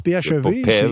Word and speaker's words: PHEV: J'essayais, est-ce qu'on prononce PHEV? – Oui, on prononce PHEV. C'est une abréviation en anglais pour les voitures hybrides rechargeables.